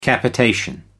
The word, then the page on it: capitation: American English